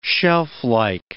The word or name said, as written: shelflike